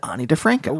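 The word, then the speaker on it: Ani DiFranco: in each episode that was gifted to me by the algorithm, Naomi mentioned Ani DeFranco.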